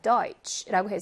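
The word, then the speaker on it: Deutsch: Deutsch라고 해서 츠 발음이에요. 트쉬라고 발음하지 않아요.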